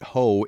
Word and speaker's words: Hoh: point out that it, that ho is spelled H O H, so it's heart of the H O H, and H O H is pronounced ho.